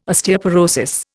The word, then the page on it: Osteoporosis: Indian